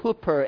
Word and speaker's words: huper: And the Greek word for is hooper, H-U-P-E-R. It's not a mispronounced word by some Korean guy that meant to say it, super. It's a hooper.